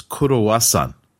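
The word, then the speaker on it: kurowasan (クロワッサン): And then I'm like, anyway, the point was that the Japanese pronunciation of croissant is kurowasan. I would never have guessed that.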